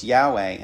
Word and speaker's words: Yahweh: It's Y-H-W-H. It has no vowels in it. – Okay. – So it's Yahweh, and that's how you pronounce it.